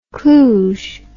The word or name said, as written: Cluj